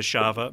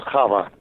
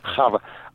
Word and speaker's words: Chava: And, of course, we have the Shava ballet sequence. Was there any thoughts about giving that couple a song? – Yeah, it's actually pronounced Chava. – Chava.